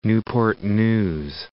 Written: Newport News

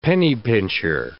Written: penny-pincher

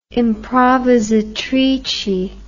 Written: improvisatrice

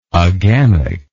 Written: agamically

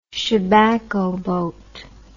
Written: chebacco boat

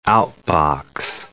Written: out-box

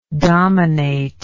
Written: dominatingly

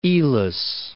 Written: Elis

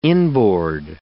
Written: inboard

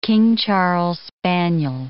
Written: King Charles spaniel

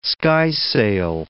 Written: skysail

